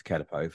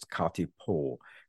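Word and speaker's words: kākāpō: but because it's a Māori word from New Zealand, it's ka te pō. But I think catapo is a generally accepted pronunciation, even in New Zealand. It's not a correct pronunciation, but it's what a lot of people would say.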